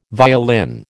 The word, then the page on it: violin: American English